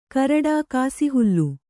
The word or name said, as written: ಕರಡಾ ಕಾಸಿಹುಲ್ಲು